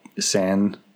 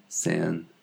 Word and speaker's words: Saint: And, you know, it's spelled Saint, but of course it's French, so it's more like San. – San.